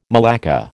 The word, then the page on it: malacca: American English